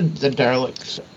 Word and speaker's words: Dalek: Oh, well, it's a pronunciation thing, but it's spelled D-A-L-E-K. – Okay. So what is that how people pronounce it, Dalek? – Yeah.